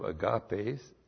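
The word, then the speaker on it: agapes (ἀγάπης): Then the genitive of agape, and it's in the genitive agapes, A-G-A-P, long E-S. Then the genitive of the article again, to, plus the genitive of Christos, which is Christu. Tes agapes to Christu.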